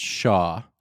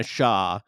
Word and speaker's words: Xia: pronounced Shaw. – Thank you. I knew you would know that. Sorry about that. Rosanna Xia,